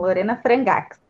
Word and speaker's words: Lorena Frangax: Hoje a gente vai conversar com a Lorena, Lorena Frangax, é assim que pronuncia? – Isso mesmo, Lorena Frangax.